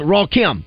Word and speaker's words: Rakim: The other one is pronounced Raw Kim. Did you get that right? – Raw Kim. Usually it's Rakim. I mean, you know, that's how I think.